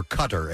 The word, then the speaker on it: Qatar: At the first Qatar Camel Festival late Tuesday, or Qatar, as some pronounce it.